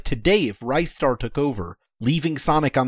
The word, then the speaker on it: Ristar: I just want to say it's definitively pronounced Ristar, by the way. I only know that because that's what it says on the title screen of the Japanese version. Ristar.